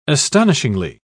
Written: astonishingly

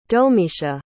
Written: domicia

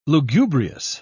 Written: lugubrious

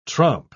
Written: tromp

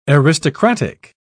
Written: aristocratic

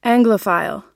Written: anglophile

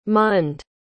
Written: mund

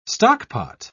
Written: stockpot

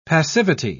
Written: passivity

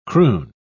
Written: croon

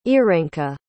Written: irenka

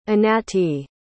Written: Anatie

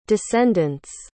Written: descendants

